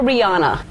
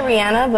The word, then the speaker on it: Rihanna: Now, I heard, is it Rihanna or Rihanna? – Rihanna. I say Rihanna, but Rihanna is still okay. – I don't know why everybody is insisting on calling you Rihanna if your name is Rihanna.